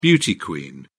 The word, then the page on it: beauty queen: British English